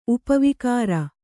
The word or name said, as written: ಉಪವಿಕಾರ